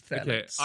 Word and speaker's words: Phthalates: Phthalates, I think. I think the F is silent. – Phthalates, yes. – That is... Oh, I say F, but it's PH.